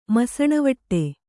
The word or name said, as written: ಮಸಣವಟ್ಟೆ